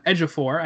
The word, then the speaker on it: Ejiofor: Chiwetel Ejiofor, I know that's how you say his last name, is Mordo.